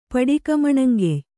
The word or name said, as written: ಪಡಿಕಮಣಂಗೆಯ್